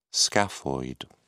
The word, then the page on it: scaphoid: British English